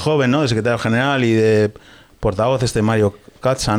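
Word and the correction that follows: Czaja: Eh, Mario Chaya. Chaya, digo, Cacha, mira, mira, qué pronunciación impresionante. Cacha a Chaya.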